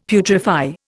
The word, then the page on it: putrefy: Indian